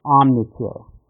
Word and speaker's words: Omniture: So, John, before you got the urge to start Exact Rail, you had co-founded Omniture. Did I say that right? – That's correct.